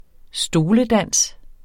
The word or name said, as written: stoledans